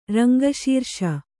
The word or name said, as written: ರಂಗಶೀರ್ಷ